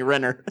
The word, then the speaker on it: Renner: He still said it wrong. It's Jeremy Renner. – Renner? I always heard Reiner. Sorry. Renner? Jeremy Renner.